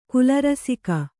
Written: ಕುಲರಸಿಕ

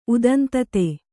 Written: ಉದಂತತೆ

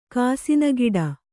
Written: ಕಾಸಿನಗಿಡ

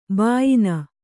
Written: ಬಾಯಿನ

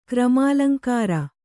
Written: ಕ್ರಮಾಲಂಕಾರ

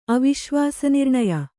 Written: ಅವಿಶ್ವಾಸನಿರ್ಣಯ

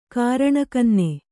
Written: ಕಾರಣಕನ್ನೆ

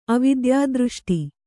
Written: ಅವಿದ್ಯಾದೃಷ್ಟಿ

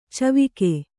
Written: ಚಿವಿಕೆ

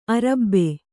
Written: ಅರಬು

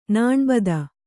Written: ನಾಣ್ಬದ

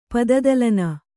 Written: ಪದದಲನ

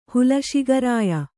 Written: ಹುಲಶಿಗರಾಯ

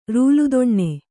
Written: ರೂಲುದೊಣ್ಣೆ

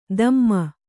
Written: ಧಮ್ಮ

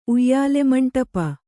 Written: ಉಯ್ಯಾಲೆಮಂಡಪ

